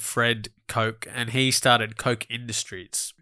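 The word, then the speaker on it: Koch: Bill Cock. – Bill Cock. – I didn't. In my head, I was like, don't say Cock. But how do we say it? – Bill Cock. – Coke. Coke.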